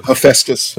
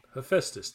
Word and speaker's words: Hephaestus: Raising the sword, her brother Hephaestus, I'm sure I'm butchering that name, had given Hephaestus. Hephaestus, yeah. – Hephaestus, thank you.